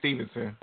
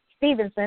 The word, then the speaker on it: Stephenson: Is it Lance Stephenson? – Yes, Baby Stevenson. – Or Stevenson, that's what I was thinking too, but it's not spelled like Stevenson. It's just Lance Stephenson.